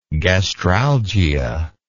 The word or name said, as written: gastralgic